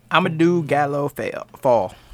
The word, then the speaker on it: Amadou Gallo Fall: I cannot pronounce this man's name. – Amadou Gallo Fall.